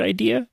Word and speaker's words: IDEA: I guess you pronounce it like the word idea.